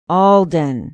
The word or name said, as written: alden